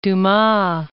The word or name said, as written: Dumas